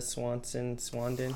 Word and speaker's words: Swinton: Tilda Swinton.